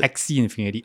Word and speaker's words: Axie Infinity: Axie Infinity. A-X-I-E.